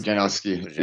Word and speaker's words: Pudzianowski: Am I pronouncing Pudzianowski? Yeah.